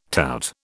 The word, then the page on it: tout: British English